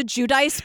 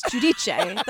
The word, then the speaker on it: Giudice: She calls herself Teresa Giudice, but it's Giudice. That's how you say it, Italian.